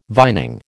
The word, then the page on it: vining: American English